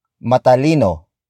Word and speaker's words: matalino: Word for word it's matalino. Ako. Matalino. Ako. Matalino. Ako. In this sentence, we use the adjective matalino. Matalino. Matalino. Matalino is spelled as M A T A L I N O.